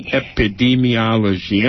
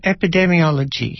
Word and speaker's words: epidemiology: The word I mispronounced, how do you pronounce that? – Epidemiology. – And what's how? – Epidemiology. Yeah. – Epidemiology.